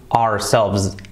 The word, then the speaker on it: ourselves: La pronunciación es casi igual, pero con una S al final, que es una Z un poquito así, ourselves, ourselves, ourselves. Suena casi igual con una S, ourselves.